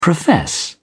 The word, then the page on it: profess: British English